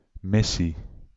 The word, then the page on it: mission: France; /mi.sjɔ̃/